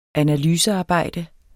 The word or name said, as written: analysearbejde